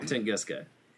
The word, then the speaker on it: Tunguska: Tunguska. So I'm glad you pronounced it first, so now that I know how to pronounce it. – It is, in fact, Tunguska.